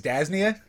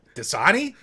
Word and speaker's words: Dasani: He's like, what, a man can't enjoy his Dasnia? And we're like, his what? He's like, Dasnia. I have my Dasnia. We're like, Dasani?